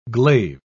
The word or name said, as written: glaive